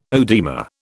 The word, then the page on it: oedema: British English